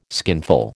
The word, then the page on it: skinful: American English